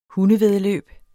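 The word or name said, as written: HUNDEVÆDDELØB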